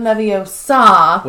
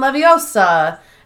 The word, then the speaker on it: Leviosa: Wingardium Leviosa, but Ronald Weasley is pronouncing it wrong. He's like, Wingardium Leviosa. And she's like, it's not Leviosa, it's Leviosa, or something like that.